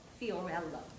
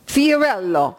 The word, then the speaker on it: Fiorello: Fiorello. – Fiorello, oh, it's Italian, Fiorello.